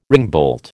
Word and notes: ringbolt: American English

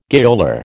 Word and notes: gaoler: American English

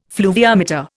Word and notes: fluviometer: Indian

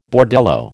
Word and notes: bordello: American English

